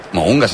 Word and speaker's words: Mounga: Si falla això Richie Mounga, no el deixen tornar a cap de les dues illes de Nova Zelanda, Que Mounga s'escriu M-O-U-N-G-A i en anglès ho pronuncien Mounga.